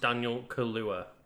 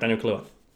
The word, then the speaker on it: Daniel Kaluuya: Apparently his name is pronounced Daniel Kaluuya. – Oh, so I did get it right, Daniel Kaluuya.